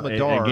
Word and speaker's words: Madar: Is it Yam Madar? – Madar.